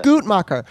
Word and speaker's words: Guttmacher: You know, on um, Guttmacher, or is it is am I saying that right? Guttmacher, Guttmacher. – Guttmacher.